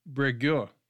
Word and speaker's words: Breguet: was a four-rotor helicopter designed by Louis Breguet. It's French, so there's I'm not pronouncing the T at the end, but it could be Breguet. But I think it's French, so you don't do that.